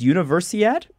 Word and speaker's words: Universiad: How do you say this? Universiad? – Yeah, you got it.